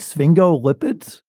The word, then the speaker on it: sphingolipids: Am I going to say it right? Sphingolipids? – Yes, you said it right.